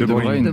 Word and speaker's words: De Bruyne: C'est-à-dire que De Bruyne, je sais pas comment il faut prononcer De Bruyne.